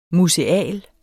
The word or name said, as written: MUSEAL